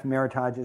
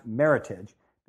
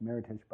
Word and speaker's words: Meritage: Well, it turns out Meritage is, it's not pronounced Meritage, it's pronounced Meritage, because it's an American term. It came out of a contest that happened in California, and it's a combination of merit and heritage.